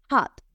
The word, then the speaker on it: hot: Esta palabra se escribe con O, H, O, T, pero es verdad que no se pronuncia como si fuera una O, hot, sino que es una mezcla entre A y O.